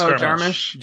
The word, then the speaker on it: Jarmusch: It's Jarmusch.